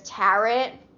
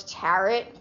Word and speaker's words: tarot: Also, for the first, like, 19 years of my life, I thought it was tarot, like, because that's how it's spelled, T-A-R-O-T. I thought it was tarot cards, but it's tarot, you know, a little type deaf action, a little deafy vibe up in here.